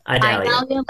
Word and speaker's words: Idalia: It's uh, it's I Idalia Idalia. – like Idalia. I got yelled at by our chief meteorologist for saying it wrong, and then I was like, you know what?